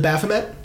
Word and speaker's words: Baphomet: The Baphomet. Yeah, that's how you pronounce it.